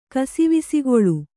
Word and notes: ಕಸಿವಿಸಿಗೊಳ್ಳು: kasivisigoḷḷu

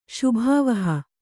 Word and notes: ಶೋಭಾವಹ: kannada

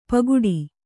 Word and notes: ಪೋಗೊಡು: Kannada

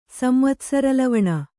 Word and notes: ಸಂವತ್ಸರಲವಣ: kannada

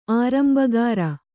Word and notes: ಆರಂಭಕಾಱ: ārambhakāṛa

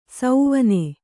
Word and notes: ಸೌವನೆ: Kannada

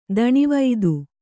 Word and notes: ತನುವಾತ: Kannada

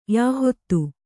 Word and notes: ಯೆಹೂದಿ: yehūdi

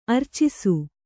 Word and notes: ಅರ್ಚಿಸು: Kannada

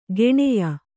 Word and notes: ಗೆಣೆಯ: geṇeya

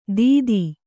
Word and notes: ದಿತಿ: kannada

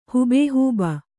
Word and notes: ಹೂಬೇಹೂಬ: kannada

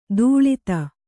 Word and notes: ತಾಳದ: tāḷada

